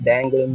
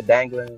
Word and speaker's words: Danglin: Instagram and Facebook, it's at Danglin Music. So, and the Danglin is spelled D A N G L I N.